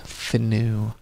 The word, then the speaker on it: Finau: Tony Finau, by the way. Finau. I mispronounced it. I always do that. – Finau. – That's how it's F-I-A-N-U, so it looks like Finau, but it's Finau.